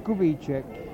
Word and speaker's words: Kubicek: John Kubicek. – Now you're going to have to spell that. – K-U-B-I-C-E-K.